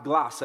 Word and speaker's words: glossa: Tongues in the Bible, in its original language, Greek, is pronounced glossa. Everybody say glossa.